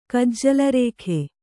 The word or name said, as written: ಕಜ್ಜಲರೇಖೆ